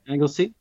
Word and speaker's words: Anglesey: We had Beth Burks run for us in Wales, Anglesey half. Is that how you pronounce it? – Anglesey.